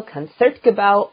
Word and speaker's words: Concertgebouw: We have special guest Gustavo Nunez, principal bassoon of the Royal Concertgebouw Orchestra. And yes, I know I'm pronouncing that correctly because he taught me how to pronounce it.